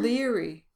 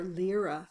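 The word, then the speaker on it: Laoghaire: Leary. – How do you pronounce it? – Leary or Lyra. I've heard it pronounced two different ways.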